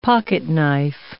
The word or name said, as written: pocketknife